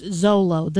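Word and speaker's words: Xolo: It's so hard to pronounce. I mean, it looks like Mr. McClixifix, whatever it was from Superman comics. – Yeah, but it's pronounced Zolo.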